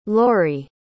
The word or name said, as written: lorie